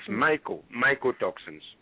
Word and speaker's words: mycotoxins: I also see in the chat room, someone asked about microtoxins in the corn. Do you know anything about microtoxins? – Yeah, they're not Mike. I don't think the person said it right. It's mycotoxins. Mycotoxins come from fungi, fungus.